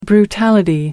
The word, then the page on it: brutality: American English